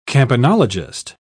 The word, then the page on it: campanologist: British English